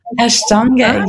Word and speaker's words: Ashtanga: Ashtanga, yes.